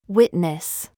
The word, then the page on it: we: American English